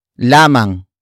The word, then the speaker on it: lamang: The next word is lamang. Lamang. Lamang. Lamang is spelled as L-A-M-A-N-G.